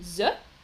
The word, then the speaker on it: œufs: Je répète, on dit donc des œufs des œufs.